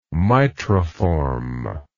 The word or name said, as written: mitriform